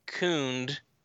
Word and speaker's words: Kund: He says, I always pronounced Kund to rhyme with book. So I'm not sure. I guess that would be Kund. – It avoids the whole ooh, like, loot thing.